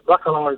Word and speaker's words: Bacalar: Yes, Bacalar is the right way to pronounce it.